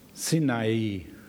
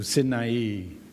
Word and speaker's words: Sinai: That's the way we pronounce it in English. Andrew's here as an English teacher to correct me. In German, we say Sinai. It's Sin that got them to Sinai.